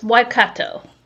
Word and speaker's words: Waikato: It's Waikato. – Waikato.